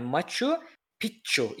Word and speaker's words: Machu Picchu: Machu Picchu okunuşunda iki ikinci yani Machu Picchu ikincisinde iki Ç varmış. İki Ç evet. Evet, Keçuva dilinde bir şeymiş bu.